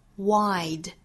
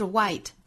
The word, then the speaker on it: wide: My ocean's deep 后面 my river's wide 这里的 deep 和 wide，注意这两个元音发音，一个是紧音的 e deep My ocean's deep My ocean's deep，那第二个元音呢是 my river's Wide 是双元音的 I 的发音，不要发成我们汉语之中这个 I 的发音，不是 wide，是 wide，上下的开口度还要更大，之后要注意滑动到后面一个小元音上。